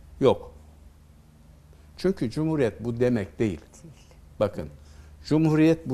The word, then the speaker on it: cumhuriyet: Şimdi cumhuriyet sözcüğü, bakın şimdi cum aslında H harfli ince okunuyor. Hü uzatıcı. Hü diye ü ile. Cumhuriyet. Orijinali bu.